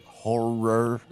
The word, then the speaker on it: horror: I just want to reaffirm my pronunciation on that was horror. – Horror. – A lot of times with my accent, I forget, you know, the second syllable.